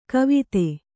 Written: ಕುವತ್ತು

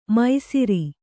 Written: ಮೈಸಿರಿ

